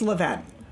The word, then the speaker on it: Levain: Well, you're close, but it's Levain. Yeah.